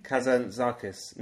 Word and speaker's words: Kazantzakis: Kazantzakis.